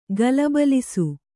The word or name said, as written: ಗಲಬಲಿಸು